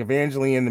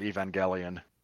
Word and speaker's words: Evangelion: Other debuts this week, Evangeline, The Mean One, Empire of Light, Christmas, Bloody Christmas.